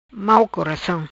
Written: Mau coracam

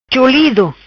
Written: Cholido

